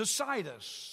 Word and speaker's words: Cnidus: After leaving Myra, he goes to Cnidus, C-N-I-D-U-S. The N is silent.